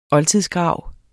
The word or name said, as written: oldtidsgrav